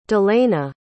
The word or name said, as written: dylana